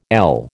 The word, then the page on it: ell: American English